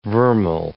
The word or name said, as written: vermeil